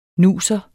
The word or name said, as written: nuser